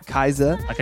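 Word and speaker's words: Kiesza: That is how to say it, Kaiza. – Like a Kaiza roll.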